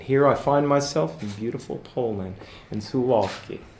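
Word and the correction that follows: Suwałki: Suwalki, I'm so sorry. Suwalki. – Yes, it's difficult to pronounce. – I just have to remember the W's or V's.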